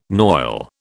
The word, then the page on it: noil: British English